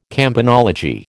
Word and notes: campanology: American English

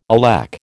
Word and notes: alack: American English

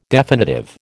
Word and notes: definitive: American English

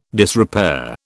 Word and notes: disrepair: British English

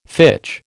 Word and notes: fitch: American English

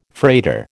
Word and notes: freighter: American English

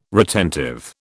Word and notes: retentive: British English

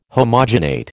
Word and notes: homogenate: American English

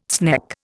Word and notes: sneck: Indian

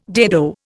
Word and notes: dado: Indian

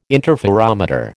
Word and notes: interferometer: American English

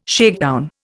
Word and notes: shakedown: Indian

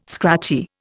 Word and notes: scratchy: Indian

